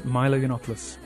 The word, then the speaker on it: Milo Yiannopoulos: Milo Yiannopoulos.